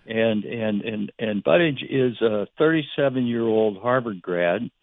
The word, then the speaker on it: Buttigieg: I do have a long-shot interest in one candidate who's the most unusual in the field, a guy named Pete Buttigieg, B-U-T-T-I-E-G-I-E-G, a very strange name. Buttigieg is how you pronounce it.